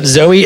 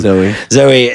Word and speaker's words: Zoi: Just check out Zoe. It's spelled really cool. Z-O-I is her first name. – Zoe.